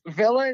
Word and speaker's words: Villa: Yeah, it's Villa.